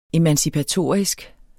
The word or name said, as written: emancipatorisk